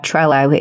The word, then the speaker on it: Trello: That's how you say it, right? Trello? – It's great. Yeah, Trello. It's really good.